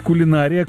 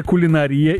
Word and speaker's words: кулинария: Как правильно говорить, кулинария, кулинария или допустимы оба варианта? – Допустимы оба варианта.